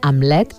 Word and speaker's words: Hamlet: Roger Torns signa aquesta adaptació lliure en què Horacis, un director d'escena narrador, el nom del turmentat príncep danès es pronuncia Hamlet i lluita contra les coses podrides.